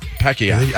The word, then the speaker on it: Pacquiao: Pacquiao. – I think. – That's how they say it. Pacquiao.